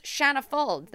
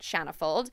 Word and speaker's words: Shanna Fuld: Also, follow us at israeldaily.news on Instagram, as well as Shanna Fuld. That's S-H-A-N-N-A-F-U-L-D.